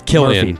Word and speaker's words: Cillian: And by the way, you spell it C-I-L-L-I-A-N. – It is Killian.